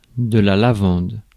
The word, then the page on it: lavande: /la.vɑ̃d/